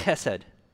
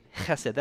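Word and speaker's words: chesed (חסד): It's the word chesed. Can you say, okay, you to, it's like clearing your throat. It's say chesed.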